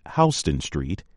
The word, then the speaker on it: Houston Street: Houston is a big city in the state of Texas. But if you are on a street in New York City's funky Lower East Side, you do not pronounce it that way. Pronounce it Houston Street, and you will keep your street cred.